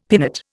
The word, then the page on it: pinnate: Indian